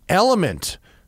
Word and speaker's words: LMNT: And we're also brought to you in part today by Element. It's pronounced Element, but it's spelled L-M-N-T because it's techie, right? Can't have vowels. You must lose all vowels.